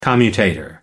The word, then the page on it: commutator: American English